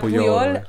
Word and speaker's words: Puyol: I said the name wrong. – Yeah, you said Poyo. I was like, huh? – No, Puyol. – Yeah, Puyol.